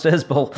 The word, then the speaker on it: Isbell: His name's pronounced Isbell.